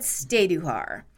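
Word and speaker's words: Stajduhar: And I asked Peyton how she pronounced it, and she said Staduhar.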